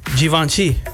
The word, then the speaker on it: Givenchy: Givenchy. – I've never even heard of that brand. – I guess you're supposed to pronounce it with a Z, according to this. It's Z-H-E-E-V-O-N-S-H-E-E. Givenchy.